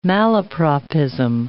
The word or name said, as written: malapropism